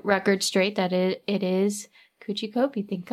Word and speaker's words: Kuchi Kopi: It's spelled K-U-C-H-I-K-O-P-I. So, you know, there were a lot of ways I could have gotten this wrong and I got it right.